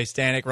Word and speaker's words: Stanek: So that's why you could say Stanek rhymes with panic, but okay, you've taken, at the very least, 2 games in this series against a Texas Rangers team that you're better than.